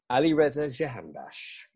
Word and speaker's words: Alireza Jahanbakhsh: Alireza Jahanbakhsh.